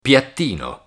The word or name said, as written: piattino